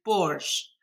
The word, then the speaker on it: borsch: Now, also another big part of your book deals with something called, and I hope I pronounce this correctly, borsch.